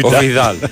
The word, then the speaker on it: Βιδάλ: Θα δω αν τραυματίστηκε ο, ο Βιντάλ. Να σε ενημερώσω ή ο Βιδάλ, δεν ξέρω τώρα πώ προφέρεται. Πάτησα στο Google και μου έβγαλε οι 22 ποδοσφαιριστές που έχει τραυματίσει ο, ο Βιδάλ. – Έτσι.